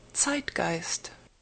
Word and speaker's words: Zeitgeist: Zeitgeist.